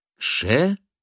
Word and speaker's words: Krzelewski: Кирсиловский, то есть Курславский». – «Ше-лев-ский», — по слогам произнес Люциус, не удержавшись. «По-польски такое сочетание букв произносится...» «Конечно!» — воскликнул ректор.